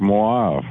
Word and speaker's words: mauve: It was always pronounced Mauve, a little bit of U in there.